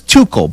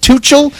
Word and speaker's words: Tuchel: Liam, so explain what happened between, I thought his name was Tuchel, but you said pronounce it Tuchel, and Jude Bellingham was not happy with these comments.